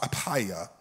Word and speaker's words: Apphia: Paul, a prisoner of Jesus Christ, and Timothy, our brother, unto Philemon, our dearly beloved and fellow laborer, and to our beloved Apphia, or, or I've heard it pronounced Apia, and Archippus, our fellow soldier, and to the church in thy house.